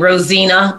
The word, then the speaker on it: Rosenna: Yes, you'll find me most places by my full name, Rosina Bakari, R-O-S-E. I'm going to say another letter, R-O-S-E-N-N-A.